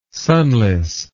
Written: sunless

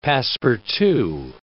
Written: passe-partout

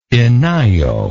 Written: enyo

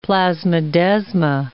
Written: plasmodesma

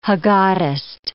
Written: haggadist

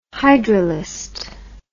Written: hydrolyst

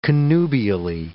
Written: connubially

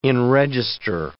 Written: enregister

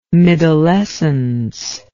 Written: middlescence

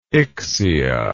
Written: ixia

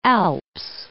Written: Alps

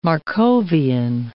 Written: Markovian

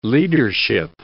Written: leadership

